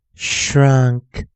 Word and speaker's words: shrunk: 第一个生词 s h r u n k，shrunk shrunk shrunk。有没有注意到 n k 去形成一个二合辅音？嗯，k，嗯，k，嗯，k。